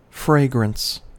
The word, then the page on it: fragrance: American English